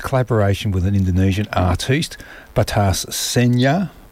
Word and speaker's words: Batas: We listened to, just now before coming back, Iron Veins by Shumo AG and Batas. How do you think? B-A-T-A-S. Which way would you pronounce the A in that? – Adam. – Batas. – Batas. Okay, I'm going to go with that.